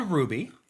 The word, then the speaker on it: Rhuby: It's called Ruby. – It is called Ruby. – Stefan would love to know R-H-U-B-Y. – That it's spelled R-H-U-B-Y.